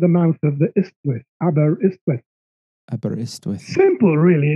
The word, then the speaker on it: Aberystwyth: In Wales, are you at, I don't know how to pronounce it, Aberystwyth? – Imagine the Y's, if you see the word, as I's. So if you say Aberystwyth, that's spot on. – Aberystwyth is the name of the river.